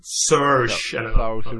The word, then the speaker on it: Saoirse: Saoirse Ronan, som är så här filmiskt perfekt utseendemässigt och söt och duktig och allting.